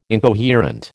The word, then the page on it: incoherent: American English